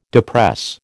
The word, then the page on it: depress: American English